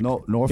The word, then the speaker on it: Norfolk: Norfolk. – Yeah. Norfolk. It doesn't actually rhyme with the word folk. It's Norfolk or Norfolk if you want to sound like you're from Virginia.